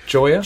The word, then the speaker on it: Gioia: A Subversive History by Ted Gioia. I'm not sure of his name. – Gioia. Pronounced Gioia.